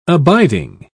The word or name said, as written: abiding